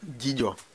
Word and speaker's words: Dzidzo: Gillo. – Gillo. – Gillo. ¿Qué se escribe D-Z-I-D-Z-O, Gillo.